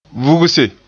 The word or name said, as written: vugse